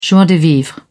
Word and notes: joie de vivre: British English

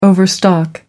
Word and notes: overstock: American English

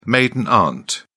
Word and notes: maiden aunt: British English

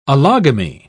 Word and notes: allogamy: British English

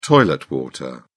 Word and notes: toilet water: British English

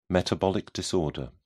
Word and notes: metabolic disorder: British English

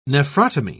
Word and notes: nephrotomy: British English

